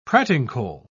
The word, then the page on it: pratincole: British English